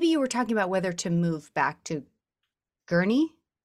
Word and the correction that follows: Guernsey: Did I say it right? – Guernsey.